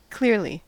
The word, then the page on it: clearly: American English